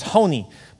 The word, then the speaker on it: Honi: There was a priest, his name was Honi, but they would actually say Honi, Honi. Say that, Honi, because it was C-H-O-N-I, but actually we would say it like Honi.